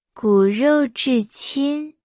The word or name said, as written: 骨肉至亲